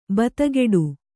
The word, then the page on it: ಭೇದಗೆಡು: bhēdageḍu